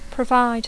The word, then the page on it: provide: English